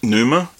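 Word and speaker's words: Pneuma: Numa. Hey. – It's P-N-E-U-M-A colon Breath of Life.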